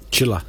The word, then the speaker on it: Chilla: Chilla. – Chilla. – In Italian, it's pronounced Chila, but it's Chilla.